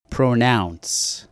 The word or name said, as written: pronounce